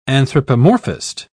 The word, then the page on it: anthropomorphist: British English